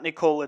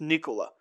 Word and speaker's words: Nikola: It's not Nikola, it's Nikola. And this allows Thaddeus Young to continue playing the four off the bench.